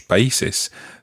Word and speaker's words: país: And it's the accent on the I that makes it País. País. – País. – It's spelled P-A-I with an accent S.